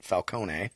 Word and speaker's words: Falcone: I mean, it's not how my family pronounces it, Falcone.